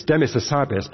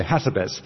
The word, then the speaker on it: Hassabis: it's Demis Hassabis, but they say Hassabis. – Yes. – And I say, no, no, you have to put emphasis on the right syllable.